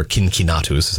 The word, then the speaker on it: Cincinnatus: Stories of Rome is the story of Cincinnatus, or Cincinnatus is how you'd actually pronounce it in Latin.